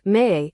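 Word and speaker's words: me (μή): Greek word me, pronounced me, is used for not, and it denies even the idea, so is a very strong negative.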